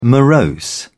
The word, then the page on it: morose: British English